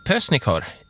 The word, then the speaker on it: Pösnikör: Välkommen in i programmet Adolf Pösnikör i Ronneby. – Jaha, hej, hej, tack så mycket. – Hej Adolf, uttalar jag ditt efternamn rätt? – Ja, ja men, jo då.